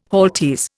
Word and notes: halteres: Indian

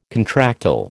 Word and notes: contractile: American English

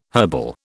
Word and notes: herbal: British English

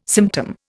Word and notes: symptom: Indian

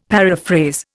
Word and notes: paraphrase: Indian

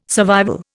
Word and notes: survival: Indian

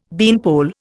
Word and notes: beanpole: Indian

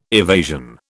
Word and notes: evasion: British English

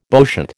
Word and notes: bowshot: American English

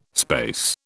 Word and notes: space: British English

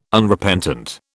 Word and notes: Unrepentant: British English

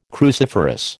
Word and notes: cruciferous: American English

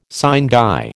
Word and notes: sine die: American English

